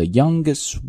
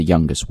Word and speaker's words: youngest: 而是直接这个 t 就不读了啊，甚至是都连这个留时间都不要留，所以不要读成 the youngest woman 啊，有这样一个停顿感，直接就是 the youngest woman。